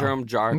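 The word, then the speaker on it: Jerome Jarre: So Grace Helbig was there, Marcus Johns was there, Jerome Jarr, I think that's how you say his name. – Yeah. – Jerome Jarr.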